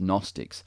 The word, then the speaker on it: Gnostics: Gnostics. The G is silent in English.